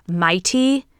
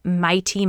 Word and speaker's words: Maitee: So her name looks like Maddie Miles, but you pronounce her first name Mighty. So it's spelled M-A-I-T-E-E, Mighty Miles.